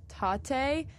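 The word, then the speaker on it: Tatte: It's called Tate. It's spelled like latte, but with a T.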